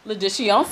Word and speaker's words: L'addition: L'addition, s'il vous plaît. – L'addition. – L'addition. – L'addition, s'il vous plaît. – Right. The L follows into, like, addition.